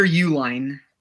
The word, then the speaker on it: Uline: Yeah, Peter Uline.